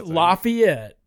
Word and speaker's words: Lafayette: Lafayette. And that's how you say it. It's Lafayette.